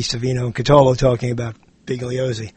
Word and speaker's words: Bugliosi: I was thinking about you not because of Bugliosi. Did I say that right? – Yes. – Yeah, Vincent Bugliosi. – Vincent Bugliosi.